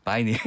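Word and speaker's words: vinyl: 미국에서는 이거를 바이닐이라고 하거든요. 네. 비닐이에요 그냥. 요 재질이 비닐 재질이랑 에, 똑같은데 에. – 우리 발음으로 비닐. – 네, 비닐. 근데 미국에서는 그냥 바이닐.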